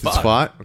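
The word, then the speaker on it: Pfaadt: It's Fott. Okay. Not fat.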